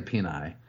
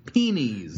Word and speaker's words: penes: The smart aleck will say peni, but you're wrong. – Is it penis? – The plural of penis is, in fact, penis, P-E-N-E-S, pronounced, wait for it, P-E-E-N-E-E-Z, penis.